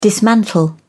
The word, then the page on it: dismantle: British English